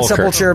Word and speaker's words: Sepulcher: I've heard sepulcher, but I think sepulcher is. – Sepulcher is correct.